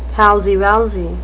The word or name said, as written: palsy-walsy